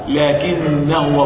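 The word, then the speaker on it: لكنا: وربما تنظر في كتاب الله فتجد ألف في الوسط لا ينطق، أما في الوسط ينطق مادة طبيعية، تعرف قول الله تعالى لكن هو الله ربي، لكن هو الله، لكن هو الله، بعد نون لكن هو، تجد مرسوما ألف لكنه لا ينطق، اسمع لكنه الله ربي، لكنه الله، إن الألف لا ينطق،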